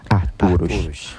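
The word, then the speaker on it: Ajt'urux: En este caso fue la libélula que se pronuncia Aturush.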